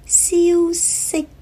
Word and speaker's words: 消息: 正确的发音是 sick sick，消息，消息，消失，消失。注意了，消息和消失，西跟失，很多朋友在发音的时候很容易把两个字混了。消息的西是 sick sick，消失的失 sad sad。